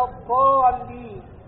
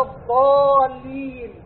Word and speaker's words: الضالين: ولذا تجد البعض يقول غير المغضوب عليهم ولا الضالين، ضاع غلط، ولا الضالين غلط، ولا الضالين غلط، وإنما العرب يقولون ولا الضالين.